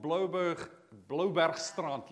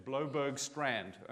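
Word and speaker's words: Bloubergstrand: to a place called Blobergstrand. Let me use it in its right pronunciation. Blobergstrand, okay, if I anglicize it.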